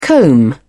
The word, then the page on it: comb: British English